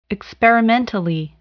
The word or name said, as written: experimentally